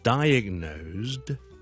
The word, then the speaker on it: diagnosed: 我被诊断咗有某个病咁啦，I was diagnosed 咧，最后面咧系一个 zd 嘅音嚟嘅，diagnosed。